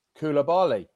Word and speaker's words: Koulibaly: Can you pronounce that one? – Koulibaly. – Yeah.